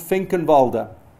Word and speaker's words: Finkenwalde: Finkenwalde.